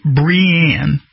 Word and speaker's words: Brian: The contestant, Brianne Wynn, which his name is spelled Brian, B-R-I-A-N, but she pronounces it Brianne, won the competition to be titled Miss Greater Derry 2023.